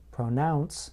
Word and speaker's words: pronounce: Note that in the word pronounce, I place stress on the second part of the word, pronounce.